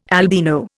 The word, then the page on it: albino: Indian